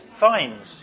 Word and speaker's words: Fiennes: Joseph Fiennes, is that how you pronounce it? Fiennes, yeah, that'll do.